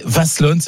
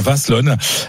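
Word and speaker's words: Wasselonne: Vous venez de Vaslone, j'apprends à bien prononcer hein, notre Alsacien du groupe Kevin Gasser m'a dit qu'on prononçait Vaslone, c'est dans le Bas-Rhin. Bonjour Sarah. – Bonjour, oui, c'est ça, vous prononcez très bien. – Un Vaslone.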